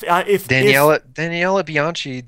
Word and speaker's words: Daniela Bianchi: If Daniela Bianchi, that's the only way to pronounce it.